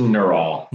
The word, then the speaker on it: Neurowl: How do you pronounce it? So I'm going to say it right. N-E-U-R-O-W-L, owl. – Yeah. Well, it's a wordplay, but it's just Neural.